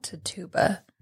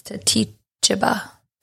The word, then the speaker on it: Tituba: It's not Tituba. It's Titichiba. Titichiba. – Titichiba? Mm-hmm. I have T-I-T-U-B-A.